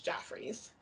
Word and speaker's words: Joffrey's: It's spelled like Jeffrey with a G, but it's Joffrey's.